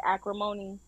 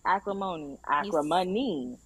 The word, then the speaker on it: Acrimony: Like, y'all seen that movie, Tyler Perry, Acrimony? Did I say it right? Mm-hmm. Acrimony. – You said it right the first time.